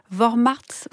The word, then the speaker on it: Vormärz: Vous avez donné le terme allemand, Isabelle Garot, c'est Vormärz. – Voilà. – C'est ça, je le dis bien.